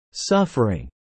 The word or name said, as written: suffering